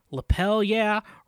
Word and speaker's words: Lapel Yeah: Lapel Yeah rhymes with hell yeah, as in give me a lapel yeah.